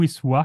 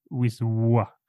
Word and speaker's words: Wisła: Det ska sägas här att eh, Wiswa kan vara ett sånt uttal som är, det stavas ju vad vi skulle, det är sånt L med ett streck igenom och jag är ganska säker på att det uttalas så det är Wisła.